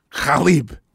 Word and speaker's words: Khalib: So it's the pronounced, the way that you say it is Khalib. – Khalib? – K-H-A-L-I-B.